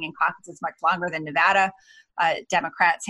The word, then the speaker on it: Nevada: Yeah, Megan's going to be out there visiting a town which bears our name, but is not pronounced the same way, which is kind of funny because we're Nevada. Always correcting people from other places about Nevada. So in Iowa, it's Nevada.